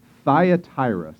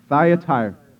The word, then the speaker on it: Thyatira: Uh, I listened to many an audio Bible this week, and they all say that it's Thyatira. Say that, Thyatira. I'll say it, then you say it. Thyatira. Right, there you go. Now you know how to say it.